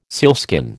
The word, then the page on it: sealskin: American English